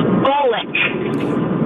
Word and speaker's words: Bolek: Bullock. – Bullock, like Sandy? – Yes, B-O-L-E-K. Felt different, but pronounced the same.